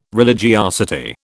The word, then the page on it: religiosity: British English